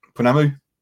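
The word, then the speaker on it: pounamu: And I'm going to obviously, unfortunately, probably mispronounce this piece. Sorry, folks, but the Punamu. Yes, thank you. – Punamu. – Yep.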